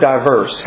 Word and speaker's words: divers: The word diverse, how's it spelled in the King James Bible? – D-I-V-E-R-S. I think it's divers. – Divers. Divers. Okay. Now you pronounced it incorrectly, sir.